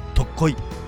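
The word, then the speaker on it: 特牛: ちょうど萩とかね、長門に近い特濃い、ね、特別の牛と書いて特濃いと読むんだけど、この地名のところにこの角島大橋っていうのがかかっているんだよね。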